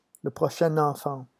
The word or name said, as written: le prochain enfant